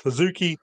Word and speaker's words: HZK: You know, Io Shirai brings her in and rechristens her HZK, which is still pronounced Hizuki, but for the case of the written version, it's HZK, which does become kind of important when we're moving forward.